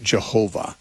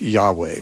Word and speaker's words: Yahweh: and pronounce it Jehovah with a J sound at the beginning. More commonly, you know, Hebrew scholars would, I think, endorse a pronunciation more like Yahweh, which is, you hear that much more often now by those who are trying to be authentic.